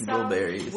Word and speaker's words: Wilburys: Traveling Warberries. – Wilburys. Wilburys. Yeah.